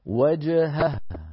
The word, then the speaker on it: وجهه: فكيف إذا توالى في النطق هاءان؟ لابد من بيانهما، فنقول هكذا وجهه وجهه. ها نبين الهائين بلطف من غير تعسف.